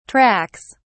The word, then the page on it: tracks: British English